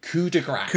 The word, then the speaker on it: coup de grâce: So you'll hear people telling you it's coup de grace. because they've heard of like Mardi Gras and assume it's pronounced the same thing. Mardi Gras, however, is spelled G-R-A-S, and yes. That means fat. – Fat Tuesday. – Good Mardi Gras. Grass is G-R-A-C-E, which is a French word meaning mercy.